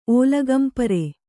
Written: ಓಲಗಂಪರೆ

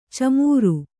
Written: ಛೀಮಾರಿ

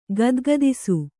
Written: ಗದಗದಿಸು

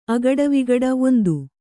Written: ಅಗಟವಿಗಟ

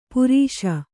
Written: ಪುರೀಷ

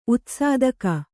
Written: ಉತ್ಸಾದಕ

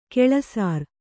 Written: ಗೌಳಸರ

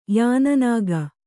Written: ಯಾನನಾಗ

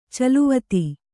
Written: ಚಲ್ಲವತ್ತ

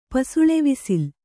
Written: ಪಸುಳೆವಿಸಿಲ್

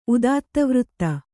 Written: ಉದಾತ್ತವೃತ್ತಿ

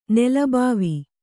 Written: ನೆಲಬಾವಿ

